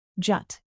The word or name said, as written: jut